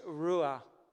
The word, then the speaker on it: Ruah: In the Hebrew, the word is Ruah. So if I would spell it R-U-A-H, Ruah, breath. We've been talking about giving thanks this morning, that there would be a giving thanks for Ruah, for the breath that we have.